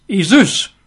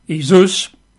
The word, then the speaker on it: Iesous: And in the Greek, it's Iesus. They write it down in our letters, I-E-S-O-U-S, Iesus. Mm-hmm. That's how they would pronounce it, probably, Iesus.